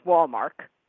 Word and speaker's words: Walmark: The problem with a name that seems so simple and easy to pronounce is it gets confused for other similar names, like Hallmark and Walmart. But it's Walmark.